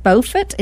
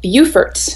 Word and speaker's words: Beaufort: And after she sold the clinic in 2008, she moved her family to, is it Beaufort? Is that how you pronounce it? – In North Carolina, that is how you say it. But in South Carolina, where I live, it's pronounced Beaufort.